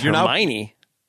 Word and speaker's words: Hermione: I mean, I'm no Potterhead, but I believe it's Hermione.